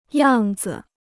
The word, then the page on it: 样子: yàng zi